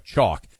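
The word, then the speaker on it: Choq: Chalk. That's C-H-O-Q spelled with a Q.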